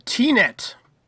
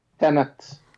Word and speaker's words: Tenet: It's the same movie you just said. Tenet. What did you say? – Tenet. – I thought it was pronounced T-Net, and I thought it was about drama on the internet.